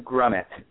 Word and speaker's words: Grumet: It's Jordan Grumet, but people say Grumet.